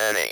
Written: any

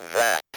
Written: that

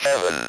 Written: heaven

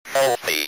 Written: healthy

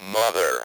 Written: mother

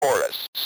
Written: chorus

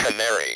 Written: canary

